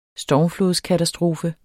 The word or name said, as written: stormflodskatastrofe